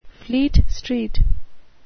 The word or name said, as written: Fleet Street